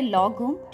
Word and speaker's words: lagom: So now, our next word is lagom. It's L-A-G-O-M. And it's pronounced as lagom.